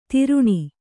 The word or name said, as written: ತಿರುಣಿ